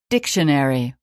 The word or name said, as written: dictionary